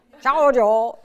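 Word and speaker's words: Zhaozhou: In Chinese, we use Japanese because we can't pronounce Chinese. So much easier, Joshu. Chao Jo. Good? – Yes.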